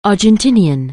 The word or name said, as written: Argentinian